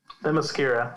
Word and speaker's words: Themyscira: Themyscira.